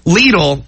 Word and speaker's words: Lidl: Lidl. Am I saying that right? Yeah. Lidl, which we learned a lot about that yesterday.